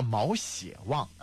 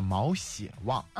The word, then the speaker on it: 毛血旺: 好，还有一个问题，毛血旺大家都吃过吧？ – 吃过啊。 – 对，应该叫毛血旺，对，叫这个发音叫毛血旺，对。